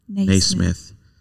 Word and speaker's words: Naismith: Scott Flansburgh also said that he is not sure that James Naismith, did I say that right? – Naismith invented basketball.